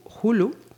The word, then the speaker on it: Hulu: Y es que eh, Hulu, creo que se pronuncia así, sí.